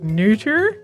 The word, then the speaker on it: Noether: So it's Emily Noether. You'll sometimes also hear it pronounced more like Noether.